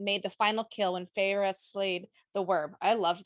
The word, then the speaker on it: Feyre: Feyre.